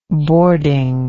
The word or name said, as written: boarding